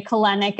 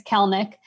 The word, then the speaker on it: Kelenic: but a lot of people say Kelenic, it's Kelnick.